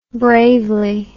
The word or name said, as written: bravely